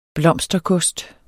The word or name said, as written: blomsterkost